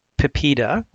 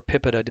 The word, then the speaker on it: PIPEDA: When the federal privacy law in Canada, the Personal Information Protection and Electronic Documents Act, PIPEDA, or PIPEDA, depending on how you'd like to pronounce it, came into effect really in stages, but mostly by 2002.